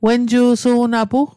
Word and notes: wenju'su'napu: wen'ju'su'napu; Mi'gmaq

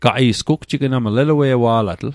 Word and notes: elue'wa'latl: Mi'gmaq

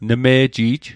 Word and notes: nme'ji'j: Mi'gmaq

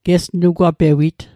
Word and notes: gesnugwapewit: Mi'gmaq

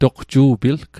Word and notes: toqju'pilg: Mi'gmaq

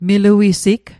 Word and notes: miluisig: Mi'gmaq

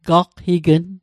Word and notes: galqign: Mi'gmaq

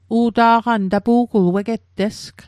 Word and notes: u'ta'qan: Mi'gmaq

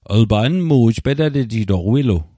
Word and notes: petatejitoq: Mi'gmaq

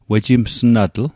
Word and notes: wejimsnatl: Mi'gmaq

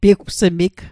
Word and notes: piwgsmig: Mi'gmaq

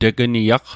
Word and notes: tegniaq: Mi'gmaq

